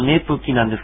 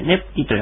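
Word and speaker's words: ネㇷ゚キ: ねぷき、ねぷきな、かなで書くとねぷきなんですが、ぷをかなではちっちゃく書きます。これ、ねぷきという発音になります。口を閉じるような発音ですね。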